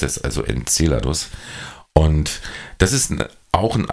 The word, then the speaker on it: Enceladus: Enceladus, so betont man es richtig. Ähm, der, das andere hört sich ein bisschen nach spanischen Maisgericht an.